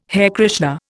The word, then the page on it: Hare Krishna: Indian